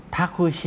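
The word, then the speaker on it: ท าคุ ชิ (タクシー): ทาคุชิทาคุชิรถแท็กซี่นะครับออกเสียงช้าๆจะออกเสียงว่าทาคุชิอิทาคุชิอิตรงชิอิออกเสียงรวบยาว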